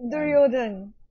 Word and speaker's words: Duryodhan: Duryodhan.